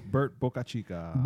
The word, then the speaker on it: Bert Bocachica: Bert Bocachica.